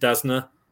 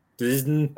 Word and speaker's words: DAZN: But apart from that, the coverage of via DAZN was I don't know if I'm saying it right. Is it Dasna? – Dasna.